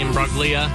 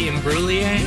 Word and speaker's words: Imbruglia: Next up, I always say Natalie Imbruglia. Just because. I don't know. It's just one of those things where I don't feel like I actually say it correctly. Natalie Imbruglia. – Imbruglia. – I like Imbruglia better, but always love Torn.